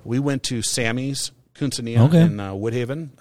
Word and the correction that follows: Cucina: What's the correct pronunciation? – I believe it's Cucina.